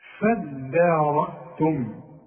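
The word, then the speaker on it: فادّارأتم: فدارتم، دي عايزة منك فن في النطق، ما تقولش فض الدال، الدال والمد بتاعها مرقق، هتقول فداء، عارف على وزن ايه فدان، شوف فدان أرض، فدان شوف الدال، هتقول لي إيه فضان ولا تقول لي فدان؟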